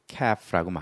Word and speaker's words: calf: 여기서 새끼라는 것은 고래 새끼, 그 다음에 송아지 새끼, 포유류의 새끼를 calf라고 말하고요. L은 묵음이에요. 그러면 이거의 복수는 뭘까요? 자, 써볼까요? calves라고 하죠. C-A-L-V-E-S.